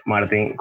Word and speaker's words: Martin: Y en Twitter como arroba Le martin con dos E, igualmente.